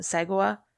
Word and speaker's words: Sagawa: I always call him Sagawa. – No, it sounds right. – I think so. It's S-A-G-A-W-A. Sagawa? Yeah. Okay.